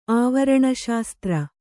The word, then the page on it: ಆವರಣಶಾಸ್ತ್ರ: āvaraṇaśastra